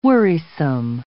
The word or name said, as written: worrisome